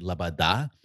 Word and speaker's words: Loboda: So her name is Loboda. That's how you say it. L-O-B-O-D-A, but you say it Labada.